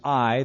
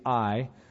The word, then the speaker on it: Ai: Some like the pronunciation Ai. That's more of the Hebrew pronunciation, the city of Ai.